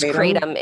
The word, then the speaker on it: kratom: People say kratom or they say kratom. I believe it's kratom is the way to say it.